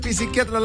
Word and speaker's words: psiquiatra: Me dijeron, no se dice psiquiatra, la P no se pronuncia.